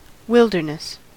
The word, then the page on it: wilderness: American English